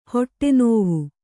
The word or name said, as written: ಹೊಟ್ಟೆನೋವು